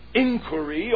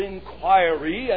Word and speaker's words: inquiry: Now then, notice in the next place, Jesus' subsequent inquiry or inquiry, and both pronunciations are correct in current English, verse 38b.